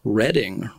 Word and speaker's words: Reading: officially, I think, pronounced Reading Writing and Arithmetic, because Reading is their hometown. They're from Reading, Berkshire, England.